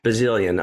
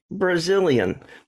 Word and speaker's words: Bazilian: I was just asked Alexa, I said, play me Eric Bazilian. And she said, Eric Brazilian. You know, so she put an R in it.